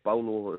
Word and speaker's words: Paulo: Is it Junior Bolo? Is that it, the correct pronunciation? – Bolo is, the correct pronunciation.